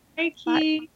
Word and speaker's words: Reiki: I wonder if it's like Reiki. Am I saying that right? Is it kind of like that? – Reiki, I would say kind of a little bit.